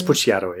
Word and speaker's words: Pucciato: Is that how you say it? I think it's Pucciato, yeah.